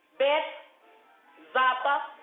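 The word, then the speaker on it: Bethzatha: And it's a pool near the sheep pool, a place some scholars pronounce it as Bethzapha. Some call it Bethesda.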